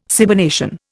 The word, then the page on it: cybernation: Indian